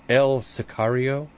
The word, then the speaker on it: El Sicario: And help me pronounce this. Is it El Sicario? – That's right.